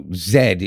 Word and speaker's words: Zed: So Zed is how it's said.